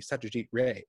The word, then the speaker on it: Satyajit Ray: I've I've looked into this because it's hard to say his name, but it's spelled Ray R A Y, but it's pronounced Ray.